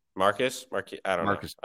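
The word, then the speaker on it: Marques Johnson: Next, we have former Bucks wing Marquise Johnson. – Marcus Johnson. I didn't look at the pronunciation.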